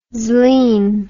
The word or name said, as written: zlin